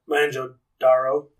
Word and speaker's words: Mohenjo-Daro: Are you talking about Mohenjo-Jaro? – Yeah, I wasn't going to try to pronounce it. – Oh, my God. Let me try to double check that pronunciation. Yeah, I'm not wrong. It's Mohenjo-Daro.